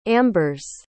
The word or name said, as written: ambers